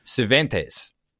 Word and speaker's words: Cervantes: But the author, it's actually, this is probably quite easy to pronounce, but it's C-E-R-V-A-N-T-E-S. Cervantes.